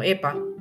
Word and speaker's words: EPA: el EPA, que se pronuncia como EPA, igual que la Agencia de Protección Ambiental, y el ácido DHA.